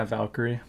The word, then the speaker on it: Valkyrie: Yeah, Valkyrie.